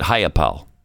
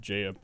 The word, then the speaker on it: Jayapal: Is that how you pronounce it? Or is it Hayapal? Because sometimes... – I've always heard Jayapal.